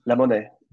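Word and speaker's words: La Monnaie: With, is it pronounced Le Monet, the opera? Yes, Le Monet.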